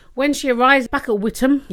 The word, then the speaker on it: Witham: It was Witham, in fact. We pronounce the T of Witham.